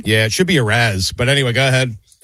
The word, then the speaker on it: Arraez: Arraez.